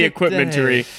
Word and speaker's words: Equipmentary: So the emphasis is on the E. Equipmentary.